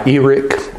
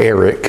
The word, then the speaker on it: Erich Sauer: by Eric or Eric, however you want to pronounce it, Sauer, or however you should have pronounced it, E-R-I-C-H-S-A-U-E-R, in the Arena of Faith.